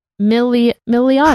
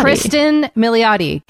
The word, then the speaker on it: Kristen Milioti: I really enjoyed Palm Springs, which is a new movie on Hulu, starring Andy Samberg and Kristen Milinotti. Is that who you say her name? – I think it's Christine Milotti. – Milioti. – Kristen Milioti. Kate got it wrong.